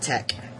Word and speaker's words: TeX: Anyway, that's, I should mention as well that it's spelled T-E-X, not, but it's pronounced Tech.